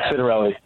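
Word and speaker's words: Ciattarelli: Cittarelli? – Jack Cittarelli.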